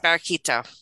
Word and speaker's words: Barraquito: Barraquito? – Yes, Barraquito.